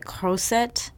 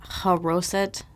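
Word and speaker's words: charoset: So I kind of mispronounced this out of habit. It's more like croset, not haroset, because figuring it out. Croset. Enjoy.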